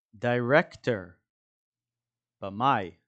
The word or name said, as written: director